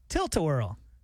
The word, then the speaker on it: Tilt-A-Whirl: You're going to be on the tilt-a-wheel. Or the Ferris whirl. You never know. – What's a tilt? I didn't say it right. – Tilt-a-whirl.